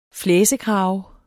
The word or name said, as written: flæsekrave